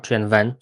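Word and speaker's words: Qwen: Mówimy o Gwen, wymawianym jako Chen Wen, co w języku chińskim oznacza po prostu tysiąc pytań.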